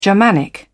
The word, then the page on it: germanic: British English